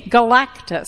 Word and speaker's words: Galactus: What happens when we put our trust in Jesus versus Galacticus? Excuse me, Galactus. I always want to add an extra syllable there.